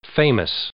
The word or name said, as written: famous